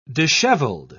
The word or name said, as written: disheveled